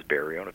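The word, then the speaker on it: Berriault: With the I-A-U-L-T at the end of it. – Mm-hmm. – And I just changed the pronunciation of Berriot, mm-hmm. – that it pronounces Berriot, and it's a little bit easier to pronounce.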